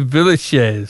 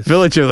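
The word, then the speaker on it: Villechaize: Oh, you did pronounce it wrong, but it is Hervé Villachez. – Villachez.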